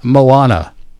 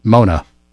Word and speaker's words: Moana: The predictions are horror films, you know, Backrooms, Obsession, The Bride, Greek Mythology, The Odyssey, K-pop Demon Hunters, Minions, Toy Story, and Moana, which I mistakenly mispronounced Mona at one time.